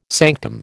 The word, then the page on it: sanctum: American English